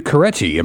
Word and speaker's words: Carecci: We got PHS English teacher Matthew Carecci. Am I saying your last name right? – You are. It rhymes with spaghetti.